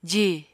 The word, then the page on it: ji: apatani